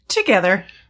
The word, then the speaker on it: Twogether: Together. – But it's T-W-O. – It's spelled Twogether. Or pronounced. – The W is silent.